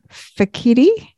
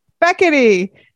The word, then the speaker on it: Fekete: And hopefully I don't butcher your last name, but it's Sharon Fekete. – Butchered. – Fekete? – Well, no, it's Fekete. – Fekete.